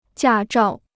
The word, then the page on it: 驾照: jià zhào